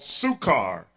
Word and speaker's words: Sychar: So he came to a town of Samaria called Sychar, and yes, I'm pronouncing that properly, near the property that Jacob had given his son Joseph.